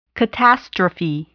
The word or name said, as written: catastrophe